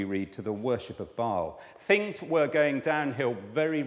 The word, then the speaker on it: Baal: He had sold out to this God who we've never heard of today, or most of us haven't, called Baal, or sometimes pronounced Baal, Baal or Baal, a local fertility god.